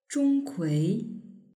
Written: zhongkui

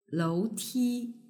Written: louti

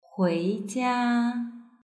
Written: huijia

